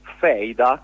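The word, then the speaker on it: Fehida: che è Feida, che si scrive F-E-H-I-D-A, l'ha appena pubblicato Minimum Fax, ed è una bellissima storia di mafia.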